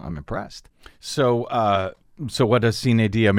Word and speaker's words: sine die: Sine die. Actually, the way that's a Latin phrase, I think it's actually pronounced sine dea. But according to my Latin teacher, it was sine die.